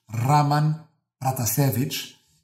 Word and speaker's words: Raman Pratasevich: ou então dizes Raman Pratasevich, que é o nome como pronunciado à maneira bielorrussa.